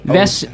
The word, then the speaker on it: Vesoj: Vesjo. – V-E-S-J-O. – V-E-S-J-O. V-E-S-J-O? Is it Vesjo? – You said O-J. – Vesoj? Vesto-J? – It's O-J, yes.